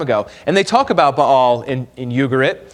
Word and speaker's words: Baal: Baal was a weather god. A lot of times we call him Baal, but the proper way to say is Baal.